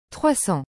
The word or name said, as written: 300